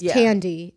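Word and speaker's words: Thandie: Her name is spelled differently than it's pronounced. It's spelled like... – spelled Thandy. but it's Tandy.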